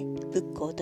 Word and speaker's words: 仏向: 正解は仏に向かうと書いて仏教と読みます